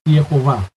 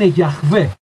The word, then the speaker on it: Γιαχβέ: Αυτό είναι το όνομα που λανθασμένα οι Εχοβάδες αναφέρουν ως Ιεχωβά. Η σύγχρονη ερμηνεία έχει αποδείξει ότι η σωστή προφορά του ονόματος είναι Γιαχβέ.